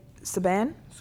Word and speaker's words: Subban: How do you say his last name? P.K.? – Subban.